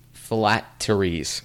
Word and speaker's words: phylacteries: What they did is they would wear these things, these necklaces, and you may have to help me pronounce this, called phylacteries. – You got it.